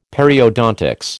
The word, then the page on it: Periodontics: American English